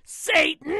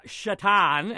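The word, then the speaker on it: Šatan: Yes, because we get to see the name, and I'm pronouncing it wrong, and I don't care, Satan, on the back of an NHL jersey again. Miroslav Shatan, as they say, is going to take Marian Hossa's spot on the first line of the Penguins.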